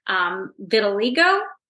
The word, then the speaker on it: vitiligo: Vitiligo, is that how you say it? – Oh, yeah.